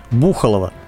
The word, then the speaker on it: Бухалово: Кстати, правильное ударение в первом топониме – Бухалово.